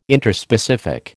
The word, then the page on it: Interspecific: American English